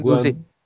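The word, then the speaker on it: Guendouzi: Ben Gu hmm. telaffuzunu söyleyemiyorum. Kusura bakmayın. Genduzi. – Guen... Heh, Genduzi. Genduzi çok beğendiğim ve potansiyelli gördüğüm oyunculardan biri.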